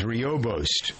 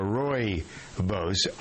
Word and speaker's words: rooibos: It's called red tea, also known as Roibost or Roibost, R-O-O-I-B-O-S, pronounced either way, has a lot of health benefits.